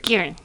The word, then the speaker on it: Guerin: Veronica Guerin. – Guerin. – That's how he said that it was said. He said, say it like Guerin really quick.